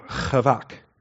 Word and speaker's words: chavak: But the CH is the guttural ch. The Q is more of the harsher k. So chavak.